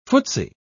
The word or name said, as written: footsie